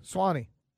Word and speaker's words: Sewanee: How do you say that school in Tennessee? – Swanee? – Is it Swanee? Okay, Swanee.